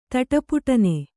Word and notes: ತಟಪುಟನೆ: Kannada

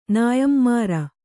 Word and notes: ನೇಯ್ಮಾರು: nēymāru

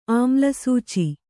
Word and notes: ಆಮ್ಲಸೂಚಿ: āmlasūci

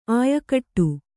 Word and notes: ಆಯಕಟ್ಟು: āyakaṭṭu

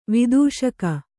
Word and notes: ವಿದೂಷಕ: vidūṣaka

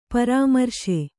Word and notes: ಪರಾಮರ್ಶೆ: Kannada

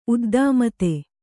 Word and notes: ಉದ್ದಾಮತೆ: uddāmate

